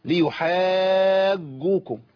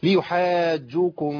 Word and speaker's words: ليحاجوكم: ليحاجوكم ما تنفعش. الجيم هي جيم وليست جيم، الجيم دي عاميه. خلاص ليحاجوكم به عند ربكم افلا تعقلون.